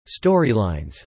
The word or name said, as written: storylines